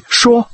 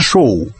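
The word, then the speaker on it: Shaw: Вот писатель, которого фамилия по-английски «Шо», по-русски произносится «Шоу». А писатель, которого фамилия «Поу», произносится «По».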